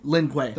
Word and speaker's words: Lin Kuei: I always say it wrong. Is it the Lin Kuei? – Lin Kuei.